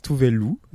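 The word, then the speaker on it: Tove Lo: Donc ça s'écrit T-O-V-E plus loin L-O et ça se prononce Tovelu.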